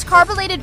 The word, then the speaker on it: carbolated: DL, I heard you mispronounce carbolated Vaseline, and you ended up saying carbonated Vaseline, and swore you were saying it right.